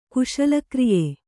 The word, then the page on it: ಕುಶಲಕ್ರಿಯೆ: Kannada